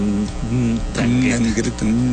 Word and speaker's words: 'Ndrangheta: Tiene una N antes, ¿tú cómo lo pronuncias? – Tendría que ser así como 'Ndrangheta. 'Ndrangheta.